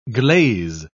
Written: glaze

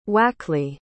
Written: Wakeley

